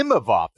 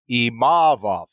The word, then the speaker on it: Imavov: Imavov, the emphasis was on the E, that first one, as opposed to Imavov, right? So a little bit of an error there.